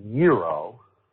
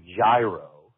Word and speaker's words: gyro: Uh, it's pronounced gyro, not gyro.